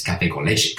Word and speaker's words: café con leche: that's not how you say it. It's café con leche, café con leche.